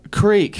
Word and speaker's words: creek: T-R-I-K. All right. Just. – How do you say it? – Creek. – Creek. – Say it in a sentence. – Down by the creek.